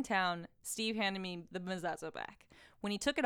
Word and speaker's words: mezuzah: I think it's mezuzah. I remember Lil Dicky saying something in an interview about his mezuzah. – Oh, mezuzah. Okay, I agree then.